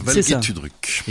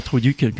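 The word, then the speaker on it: Valge Tüdruk: Valgé Tudruk. – Et pas Truduc comme j'ai dit tout à l'heure, ça marche pas du tout.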